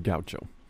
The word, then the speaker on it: gaucho: Following that, we get the story of a guacho. I said that wrong again, didn't I? – Yeah, the gaucho. – Gaucho. I don't know why I keep saying guacho.